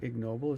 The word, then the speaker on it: ignoble: Is that the right pronunciation for that? Let's try it. Ignoble? Yeah, it looks like ignoble is the correct pronunciation there.